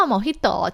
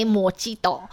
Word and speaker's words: Mojito: 面说到 Mojito，其实很多人会闹一个笑话，就是很多人看到它是拼法是 M O J I T O，都会念 Mojito。那呢，如果呢你去酒吧跟他说给我一杯 Mojito，你可能就会闹出笑话。所以记得哦，它的那个 J I J 的发音是 H。